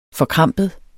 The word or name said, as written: forkrampet